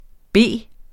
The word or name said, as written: B